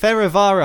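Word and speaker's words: Fehérvár: Fervar. F-E-H-E-R-V-A-R.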